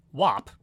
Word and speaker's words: WAP: Cardi B's new single, and I am pronouncing it the way I've been told it's pronounced, WAP, featuring Megan Thee Stallion, debuts straight in at number one.